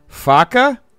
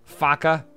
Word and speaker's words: faca: Faca? That's how you say knife. Faca. Is it because it sounds like fucker? Faca.